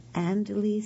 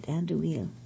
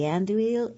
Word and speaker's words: Andouille: Oh, and 2 tablespoons of sriracha chili sauce, 2 12-ounce packages of Andalee sausage links. I did not pronounce that right. Andouille, I guess it is sausage links.